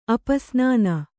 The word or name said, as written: ಅಪಸ್ನಾನ